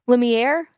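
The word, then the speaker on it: Lumière: I feel like I'm Luminaire from... did I say his name weird? – Yes. – Luminaire. How do you say it? – I don't know. Oh. Lumiere? – Yeah, that's it.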